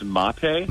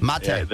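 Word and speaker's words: Maté: How do you say the guy's name? Is it Gabor Mate? – I think it's Mate. – Mate. – Yeah, there's a mark over the E.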